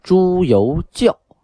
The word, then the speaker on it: 朱由校: 天启皇帝啊，他的本名叫朱由校啊。由是自由的由，那个教呢，就是学校的校啊，多音字。